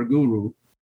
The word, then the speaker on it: guru: I, I, said it right, guru. Yeah. Guru. Mm-hmm. Okay, so I'm not your guru. – Well, at least and, that's uh, how we pronounce in English. It may be different in okay, Hindi, but great. that's how we say it in English.